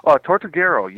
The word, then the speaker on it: Tortuguero: Tortuguero, yes.